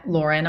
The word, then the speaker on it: Lauren: At Lauren Aboulafia. – How do you spell it? I know how to say it. – So it's at Lauren, L-A-U-R-E-N, and then my last name.